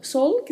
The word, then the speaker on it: sol: Sol, que significa sol, a escrita é igual à escrita do português, mas a pronúncia é diferente, porque a letra L tem esse som gerado com a língua tocando a parte superior da boca. Então falamos sol, sol, e a letra O mais fechada, né?